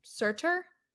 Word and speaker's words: Surtur: What if the Avengers versus Surtur? Did I say that right? – Yeah. Surtur was...